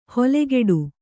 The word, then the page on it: ಹೊಲೆಗೆಡು: holegeḍu